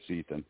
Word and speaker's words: Ethan: Do I say your name properly, Ethan? Is that it? – Yeah, it's Ethan.